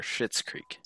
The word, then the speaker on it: Schitt's Creek: I didn't want to say the first word because my tongue always gets twisted and it sounds like I'm swearing because I would always miss the H. Oh, C-H. – Yeah. Because it's spelled S-C-H-I-T-T-S, or apostrophe S, sorry, mm-hmm.